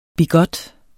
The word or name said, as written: bigot